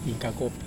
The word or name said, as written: kakopi